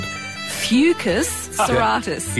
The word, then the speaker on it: Fucus serratus: Well, this stuff's called, and I've got to get the pronunciation right because I was using the wrong way. If you use the wrong way, you'd have to dump me, Rise. But it's actually called Fucus serratus.